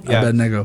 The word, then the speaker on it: Abednego: and Abednego. – Abednego.